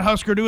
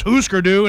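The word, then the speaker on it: Hüsker Dü: there's a song by Husker Du, and then the phone's exploded. – Uh-huh. – And some young punk rock kid calls in and he says, you idiot, it's not Husker Du, it's Husker Du,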